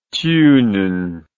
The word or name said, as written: thunen